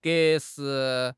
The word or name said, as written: 格式